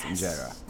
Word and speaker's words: injera: But the thing I always come back to that I'm fascinated by is the bread, the injera. Did I pronounce it right? – Yes, you did pronounce it right. It's injera.